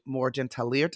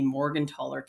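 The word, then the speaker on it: Morgentaler: Can you start off by talking about, and correct me if I say this wrong, the 1988 Morgan-Tallier decision? Yeah, did I say it right? – No. – All right, correct me, please. – So the 1988 Supreme Court decision in the R.V. Morgan-Tallier case.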